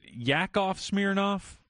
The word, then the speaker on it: Yakov Smirnoff: Is it Yakov Smirnoff? – Yes. – Or do you pronounce it with a J? – Yak.